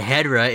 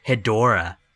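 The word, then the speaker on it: Hedorah: It's not Batra, it's Batra, and it's not Hedra, it's Hedora.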